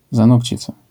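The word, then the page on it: zanokcica: Polish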